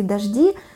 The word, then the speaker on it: дожди: Изменяются нормы, допустим, сочетание жд. Если раньше сочетание жд в словах произносилось как мягкое долгое «ж». Например, по всей территории Московской области завтра пройдут грозовые дожди.